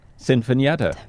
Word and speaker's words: Sinfonietta: Sinfonietta.